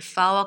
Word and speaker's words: fowl: 여기에서는 F-O-W-L, 이거는 가금류란 뜻인데 F-O-U-L도 똑같은 발음이에요, foul. 근데 그 foul은 좀 다르죠? 혹시 아시는지 모르겠는데 Julie, can you tell us the difference between the F-O-W-L and the F-O-U-L fouls? – Yeah, so pronounced the same, but foul also has a couple different meanings as well. So the F-O-U-L, sometimes when you're playing sports, whether it's soccer or football or basketball.